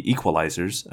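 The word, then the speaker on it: Equelizers: My episode on the Equalizers, and that's spelled E-Q-U-E-L, like sequel, but without the S, just came out earlier this week, and that was a lot of fun, so be sure to check that out.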